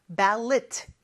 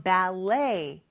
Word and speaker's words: ballet: 자, 발레는 영어로 ballet 이렇게 발음을 합니다. 근데 끝에 T가 있죠? ballet 라고 하지 않아요.